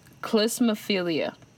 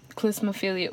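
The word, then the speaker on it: klismaphilia: Klismophilia. Wait, what do you think that is? – Klismophilia. Oh, cliff, right? – Excuse me? – You said cliff. – Cliss. – Like, oh, cliss. – It's spelled K-L-I-S-M-A-philia.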